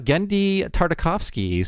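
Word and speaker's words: Genndy Tartakovsky: Let's talk about Gendy Tartakovsky. So that's how you say his name.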